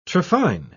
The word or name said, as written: trephine